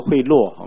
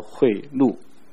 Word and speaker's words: 贿赂: 嗯，二十三节，他们各都喜爱贿赂，不要把它读作贿赂好吗？那字不读作落，它读作贿赂。